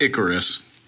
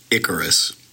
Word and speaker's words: Icarus: I never knew how to pronounce this. Help me. – It's Icarus. Icarus. Yeah.